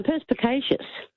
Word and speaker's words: perspicacious: I've got perspicious. – No, perspicacious.